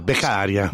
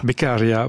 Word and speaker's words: Beccaria: La bande-annonce de votre film, Éric Bernasconi, ce film c'est Beccaria, je ne sais pas si je prononce bien correctement. – Ouais, Beccaria. – Beccaria, voilà.